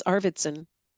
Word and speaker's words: Arvidson: It's Arvidson.